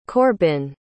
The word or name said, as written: Corbin